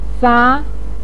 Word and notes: fa: 2

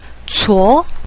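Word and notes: cuo: tone 2

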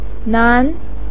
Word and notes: nan: 2

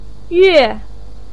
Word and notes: yue: 4

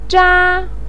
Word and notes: zha: 1

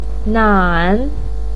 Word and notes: nan: 3

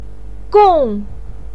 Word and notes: gong: 4

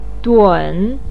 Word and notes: dun3: pinyin

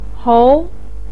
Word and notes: hou: 2

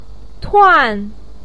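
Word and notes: tuan: tone 4

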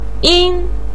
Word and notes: yin: 1

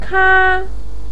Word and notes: ka: 1

